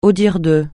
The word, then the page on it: au dire de: francais